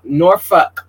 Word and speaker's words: Norfolk: Norfolk, because we're going to say it right.